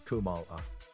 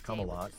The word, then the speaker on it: Kamala: It's not Carmela." She referenced the issue again in her 2019 memoir, "The Truths We Hold." – We'll keep you updated. – My name is pronounced Kamala, like the punctuation details.